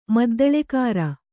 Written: ಮದ್ದಳೆಕಾರ

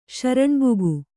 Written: ಶರಣ್ಬುಗು

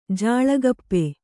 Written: ಜಾಳಗಪ್ಪೆ

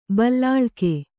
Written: ಬಲ್ಲಾಳಿಕೆ

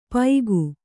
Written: ಪೈಗು

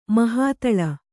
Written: ಮಹಾತಳ

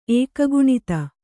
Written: ಏಕಗುಣಿತ

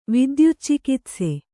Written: ವಿದ್ಯುಚ್ಚಿಕಿತ್ಸೆ

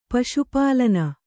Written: ಪಶುಪಾಲನ

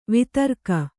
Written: ವಿದಾರಕ

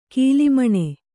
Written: ಕೋಲುಮೀನು

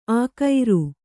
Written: ಆಕೈರು

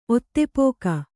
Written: ಒತ್ತೆಪೋಕ

